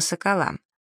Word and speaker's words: сокол: Сокол с ударением на последнем слоге — это старинное стенобитное военное орудие.